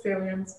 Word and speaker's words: Salience: And then I forgot to ask back at on Salient Capital V, is it Salient Capital? I feel like I'm saying it incorrectly. Salience or Salient with a T? – It's Salience, but I mean,